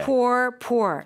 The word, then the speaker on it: pour: 아, 그리고 쌤, 그러면 P-O-U-R, pour이라고 하는 거가 P-O-O-R이라는 단어. 그것과 발음은 똑같은가요? – Yes. – Okay. – Pour, pour.